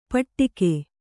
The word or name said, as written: ಪಟ್ಟಿಕೆ